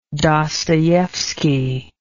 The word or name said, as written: dostoevsky